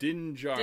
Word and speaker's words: Din Djarin: Din Djarin.